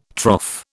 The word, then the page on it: trough: British English